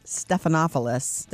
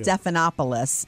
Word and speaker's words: Stephanopoulos: I'm sure that was a sit down and have the lawyers all there before, during, and after with George Stephanopoulos. Did I say it right? Yeah. Stephanopoulos.